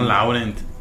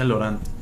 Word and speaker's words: Laurent: Sí, no Laurent. Se pronuncia Laurent.